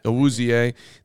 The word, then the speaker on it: Awuzie: They went nuts signing cornerbacks, Eli Apple and how do you say it? Chidobe Awuzie. Am I saying that? – Awuzie.